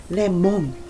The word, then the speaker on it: lemon: So they would say lemon, melon, right? But actually, the O sound is not always the O sound. There's many pronunciations for O. And for this one, for American English, we would say lemon. It would be more like the I sound, like in is, lemon.